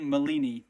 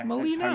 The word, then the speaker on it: Molina: Mm, Molini. Ma- I've got here, mate. – Molini, Molini. yeah. – Molina.